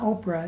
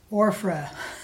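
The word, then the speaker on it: Ophrah: It's not Oprah, it's Orphra.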